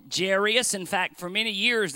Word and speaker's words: Jairus: When two vowels go walking, the first one does the talking. And so you've got A and I together. That means it's a long A. So we're going to call him Jairus, which is, I think, the correct pronunciation.